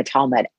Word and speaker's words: Talmud: The Talmud.